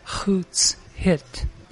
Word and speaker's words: Hoots Hit: I'm from the Brown Bear House, pronounced Hoots Hit, Hit for House.